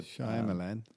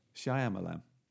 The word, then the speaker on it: Shyamalan: You know, the Shyamalan. – Shyamalan.